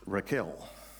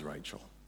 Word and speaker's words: Rachel: I like to pronounce it as Raquel, because she's a 10. Now you're showing your age. Her name is Rachel.